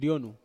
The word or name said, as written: onu